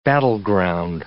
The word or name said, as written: battleground